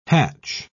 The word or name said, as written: hatch